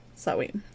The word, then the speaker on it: Samhain: The festival of Samhain, which is weird because I'm pretty sure that's how you pronounce it, but it's spelled Samhain. Samhain is how they say to pronounce it.